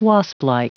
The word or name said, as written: wasplike